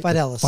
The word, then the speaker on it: Fidelis: Did I pronounce that right? – Fidelis.